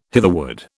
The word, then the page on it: hitherward: British English